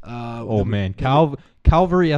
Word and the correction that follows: Cavalry: FC from Calgary. Who the fuck named that? No one can say that. – Cavalry. – Cavalry from Calgary. Yes. No matter how many times you say that right, you'll say it wrong.